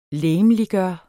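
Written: LEGEMLIGGØRE

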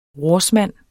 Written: rorsmand

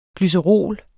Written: glycerol

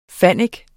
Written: fannik

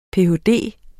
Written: ph.d.

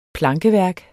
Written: plankeværk

